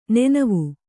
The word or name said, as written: ನೆನವಿ